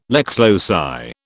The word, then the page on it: lex loci: British English